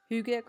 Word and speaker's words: hygge: Hugue con Mila, que se escribe H-Y-G-G-E con Mila.